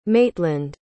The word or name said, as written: maitland